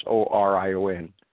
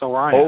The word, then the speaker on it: Orion: How do you pronounce O R I O N? – Orion. – Is that right? Is that how do you say it, Andrew? Because Andrew's listening. – O-R-I-O-N? Oh, it's Orion. – O-R-I-O-N.